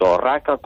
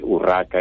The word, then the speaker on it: Orraca: tanto Orraca como Outeiro de Orraca. A tendencia a pronunciarlo con U, pero na documentación histórica nos vímolo sempre escrito con O, e por eso se mantivo con O.